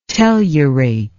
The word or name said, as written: tellurate